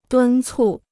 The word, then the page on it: 敦促: dūn cù